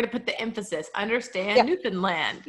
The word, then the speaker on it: Newfoundland: It's like understand Newfoundland or something like that.